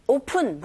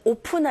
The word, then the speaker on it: often: It often 보다는 open 하시면 안 되고 often, often, F 발음 들으셔야 돼요. P가 아닙니다.